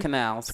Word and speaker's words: Canals: Um, it's their inaugural event, and they have a panel of the Pose cast and also Janet Mock and Steve um, Channels, and Stephen I, Canals.